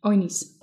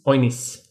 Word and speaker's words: Oignies: Maybe because I don't think you pronounce the G. Oignies. – Oignies.